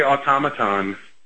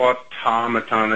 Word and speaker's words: automaton: I say automaton. I put the emphasis on the second syllable.